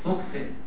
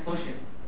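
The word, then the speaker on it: fuchsin: راح جايب صبغه ثالثه مختلفه عن الاولى في اللون اسمها بايليوتد، يعني هو خففها اهو، كاربول فوكسن، هو النطق الحقيقي ليها فوشن لكن هتسمعها في المعمل عندنا فوكسن، تعودوا على نطقها كده مش مشكله.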